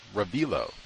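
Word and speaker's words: Revilo: You can read, I guess, Mr. Spengler and Mr. Ravilo, I think is how you say it. Ravilo. Yeah, Ravilo Oliver.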